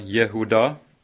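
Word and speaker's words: Jehuda (יהודה): Juda, eller Jehuda, som det uttales på hebraisk, betyr eh, lovprisning eller takksigelse.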